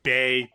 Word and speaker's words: Bey: So Bey, B, what? It's B-E-Y. It's Beyonce. – It rhymes with hey.